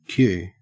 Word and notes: queue: Australian English

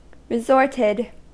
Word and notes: resorted: American English

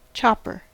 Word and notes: chopper: American English

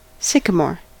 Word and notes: sycamore: American English

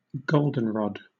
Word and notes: goldenrod: British English

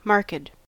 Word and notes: marked: American English

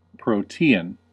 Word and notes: protean: American English

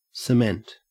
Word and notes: cement: Australian English

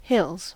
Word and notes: hills: American English

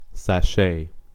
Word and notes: sashay: American English

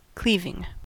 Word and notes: cleaving: American English

